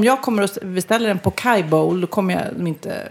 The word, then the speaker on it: poke: Men då uttalar jag den helt fel. Pokeball. För den uttalas pokai. – Va? – Ja. – Pokai bowl. – Hur konstigt. Stavas poke bowl med W. Och uttalas pokai.